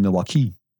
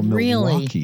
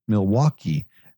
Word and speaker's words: Milwaukee: She kept saying Milwaukee, and I said, no, Milwaukee. – Really? – Just put the accent on the wa. Milwaukee.